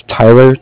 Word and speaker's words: Tyler: My name is Tyler, T-Y-L-E-R, mm-hmm. And I'm calling with Cardiff. It's spelled C-A-R-D-I-F-F, as in Frank.